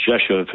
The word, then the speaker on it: Rzeszów: A town called Rzeszów, which is Rzeszow, and it's pronounced Rzeszów, and that's where President Biden spoke to the troops.